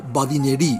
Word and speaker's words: badinerie: Badinerie se escribe badinerie y solemos pronunciar en castellanizado badinerie en español. Con B, que con V sería otra cosa.